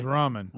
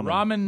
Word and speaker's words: ramen: Oh, just like Roman, but it's ramen. All right, Adler, you should R-A-M-E-N.